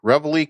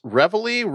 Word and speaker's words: Reveille: Brian, you pronounced Reveille correctly. And to that, I have to say, something new I have. He says, Reveille, Reveille, all hands heave out and trice up.